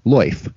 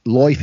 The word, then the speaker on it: lyfe: They propose introducing a broader category called loif, pronounced in an oddly West Country fashion as loif. It's spelled L-Y-F-E, of which loif as we know it is just one variation.